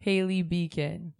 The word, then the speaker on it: Hayley Beacon: is Hayley Beacon.